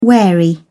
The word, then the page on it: wary: British English